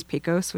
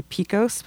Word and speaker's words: Pecos: We played a venue called Trans Pecos, which is very, I'm from Texas, so it's hard for me to say Pecos, but that's how they say it. I would say Pecos.